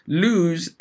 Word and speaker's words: lose: Now, what's even worse is we have lose and loose. See this an awful lot. Lose, L-O-S-E, doesn't sound like chose, whatever. The English language, what can you say? And then loose, which is L-O-O-S-E, spelled like choose, but not pronounced anywhere near the same.